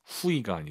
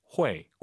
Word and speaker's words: 会: 후이가 아니라 회. 꼭 기억하세요.